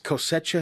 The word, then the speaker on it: Cosecha: A couple months ago, we had, Overflow Harvest Cosecha, is that how you say it in Spanish? – Yeah.